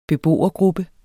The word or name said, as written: beboergruppe